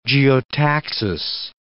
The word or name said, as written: geotaxis